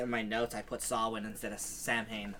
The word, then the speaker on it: Samhain: So it's actually Samhain, which I thought was really weird. – Samhain. – That's how it's pronounced? – That's how it's pronounced, yeah. – Samhain. – How the fuck do you get... Okay, it's Samhain, right? – They said that it was... It's an... It comes from Irish.